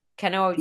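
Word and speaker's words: Kannauj: In the 19th century European perfumery, again, this says attar of roses meant the distilled essential oil of rose in, how do you say it, Canouge? It's K-A-N-N-A-U-J. – Canouge.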